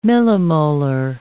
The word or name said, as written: millimolar